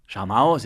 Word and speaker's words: Σαμαός: Ο Σαμάος. Ναι, ο, Σα, ο Σαμάν. Ο Σαμάν. ναι, το κυπριακό του είναι ο Σαμάος. Σαμάος. Ο Σαμάος. Σαμαός, έτσι έτσι. Σαμαός. Σαμαός, προφοράνταω.